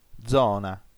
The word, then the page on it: zona: dzò.na; ['dzɔna]